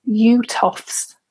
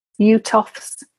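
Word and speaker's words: Uhthoff: The next one that I want to make sure you're aware of is something that's called UTOFS phenomenon. So UTOFS is spelled UHTHOFFS, UTOFS phenomenon. And I'm putting this in with the visual issues of MS, just to make you aware.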